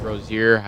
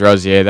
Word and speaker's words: Rozier: Terry Rozier. – Scary. Okay. It's actually pronounced Rozier. I looked it up. – Oh. – I thought it was Rozier. That made the most sense.